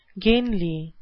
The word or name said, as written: gainly